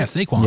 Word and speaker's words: Saquon: Saquon Barkley? Is that how you say it? – Yeah, Saquon.